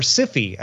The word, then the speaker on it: Sci-Fi: The Sci-Fi Channel, or SIFI, as some people pronounce it, will change its logo on June 19th for its 25th anniversary.